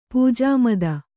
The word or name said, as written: ಪೂಜಾಮದ